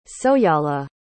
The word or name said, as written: soyala